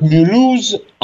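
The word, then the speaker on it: Mulhouse: То есть я вам вот прям рекомендую сейчас в интернете, да, нашим слушателям, забить музей Мюльхаузена. Находится музей братьев Шлюмф. – Извини, Слав, я просто... По-моему, Мюльхоузе пишется. Потому что французы, да, по-моему, это называют Мюлюз, а немцы называют этот город именно так.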